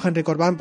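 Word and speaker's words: Henry Corbin: Si lo buscáis en internet, digo Henry Corbán porque creo que más o menos así se pronuncia en francés, pero eh, se escribe Corbin con B, Corbin.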